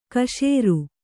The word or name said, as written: ಕಶೇರು